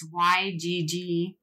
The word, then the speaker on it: Yggdrasil: It's Yggdrasil.